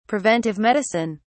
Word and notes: preventive medicine: British English

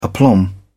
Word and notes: aplomb: British English

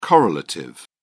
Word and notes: correlative: British English

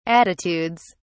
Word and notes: attitudes: British English

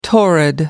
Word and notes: torrid: American English

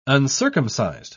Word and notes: uncircumcised: British English

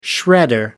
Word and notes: shredder: American English

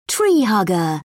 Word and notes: tree hugger: British English

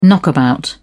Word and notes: knockabout: British English

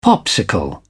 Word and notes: popsicle: British English

